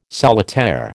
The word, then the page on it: solitaire: American English